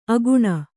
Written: ಅಕ್ಕಣಿ

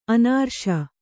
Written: ಅನೌರಸ

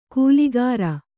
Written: ಕೂಲಿಕಾರ

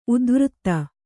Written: ಉದ್ವೃತ್ತಿ

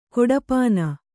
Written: ಕೊಡಪಾನೆ

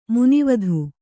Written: ಮಾನವತೆ

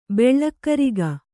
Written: ಬೆಳ್ಳಕ್ಕರಿಗ

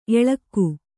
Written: ಏಳಕ್ಕಿ

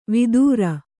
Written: ವಿದೂರ

